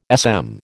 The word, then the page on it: SM: American English